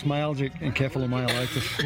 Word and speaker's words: myalgic encephalomyelitis: I'll let you pronounce it, because... – Yeah, it's myalgic encephalomyelitis.